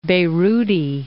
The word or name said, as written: Beiruti